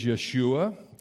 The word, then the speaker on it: Yeshua: Really, that's the English pronunciation. It really is Yeshua.